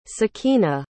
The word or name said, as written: sakeena